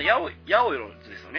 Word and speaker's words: ヤオヨロズ: ではですね、ネイジャマトコさんのお話をちょっとですね、今回、次はちょっとメインに話してみようと思うんですけど、まず、ヤオヨロズですよね。読み方は、はい、あのイントネーションは。 – あ、はい、ハイローズと一緒です。